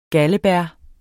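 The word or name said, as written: galdebær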